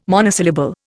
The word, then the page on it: monosyllable: Indian